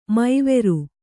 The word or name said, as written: ಮೌವರಿ